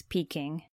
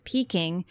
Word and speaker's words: Peking: Beijing, meaning northern capital, is how it's pronounced in Mandarin, but Peking was the way it was pronounced and spelled by foreigners back in the olden days.